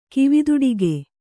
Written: ಕಿವಿದೊಡಿಗೆ

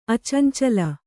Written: ಅಚಂಚಲ

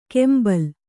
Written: ಕೆಂಬಲ್

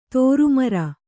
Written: ದುರ್ಮಾರಿ

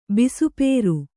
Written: ಬಿಸುಪೇರು

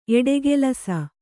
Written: ಎಡೆಗಲಿಸು

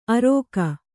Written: ಅರೋಗ

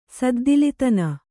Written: ಸದ್ದಿಲಿತನ

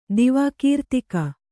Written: ದಿವಾಕೀರ್ತಿಕ

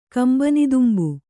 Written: ಕಂಬನಿದುಂಬು

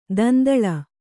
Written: ದುಂದುಲು

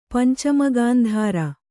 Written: ಪಂಚಮಗಾಂಧಾರ

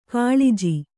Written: ಕುಲಜ

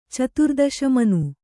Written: ಚತುರ್ದಶಮನು

